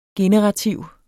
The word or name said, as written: generativ